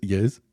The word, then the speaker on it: Eus: It's spelled E-U-S. – Yes. – Yes. – Yes. – Her name is Yes.